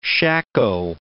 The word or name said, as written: shako